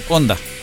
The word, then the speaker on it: Honda: Honda, en Chile le dicen Honda, porque la H no suena.